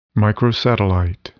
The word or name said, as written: microsatellite